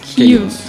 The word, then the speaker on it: Kyuss: Principalmente pelo vocalista, guitarrista e compositor Josh Homme, que antes de começar o Queens of the Stone Age, ele trabalhava na Kills. – Que a gente se escreve K-Y-U-S-S, né?